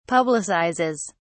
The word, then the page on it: publicizes: British English